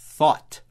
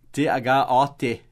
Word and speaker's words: thought: T-H-I-N-K. Think. O passado de think é? Thought. Thought. T-H-O-U-G-H-T. Thought. Thought. Não preocupe com a pronúncia do GH, porque simplesmente sabe que é thought. Tem americanos que escrevem assim, T-H-O-T, porque é assim que a gente fala, né? Thought, T-H-O-T, mas é T-H-O-U-G-H-T, né?